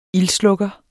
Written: ildslukker

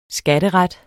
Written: skatteret

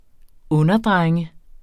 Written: underdrenge

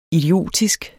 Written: idiotisk

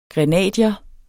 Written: grenadier

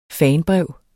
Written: fanbrev

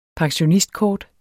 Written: pensionistkort

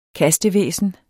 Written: kastevæsen

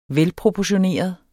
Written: velproportioneret

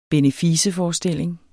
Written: beneficeforestilling

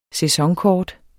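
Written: SÆSONKORT

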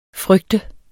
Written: frygte

